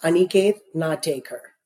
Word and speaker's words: Aniket Natekar: Sure. It's pronounced Aniket Natekar.